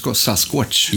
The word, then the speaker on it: Sasquatch: Nu säger du Bigfoot här, men egentligen vill du säga det Sasquatch. Ja. Uttalar jag det på rätt sätt? Ja, det gör du nog.